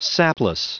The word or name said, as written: sapless